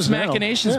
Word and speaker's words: machinations: It's machinations, yeah. But it's spelled like machine, and it always gets a little tricky.